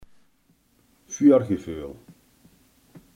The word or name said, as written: voorgevoel